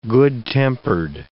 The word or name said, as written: good-tempered